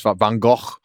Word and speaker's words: Van Gogh: So you can do like Monet's, Van Gogh's, or like, I'm not pronouncing it right. It's Van Gogh.